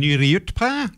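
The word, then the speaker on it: Nuriootpa: As I was saying, it's dropped down to 6.9 degrees here now in downtown Nuriutpa. Notice how I said that correctly.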